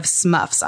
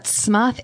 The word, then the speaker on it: SMUHF: So those of you at home can't see this, but SMUHF is an internet slang acronym that stands for Social Media User Hurting My Feelings. The M is silent in this acronym.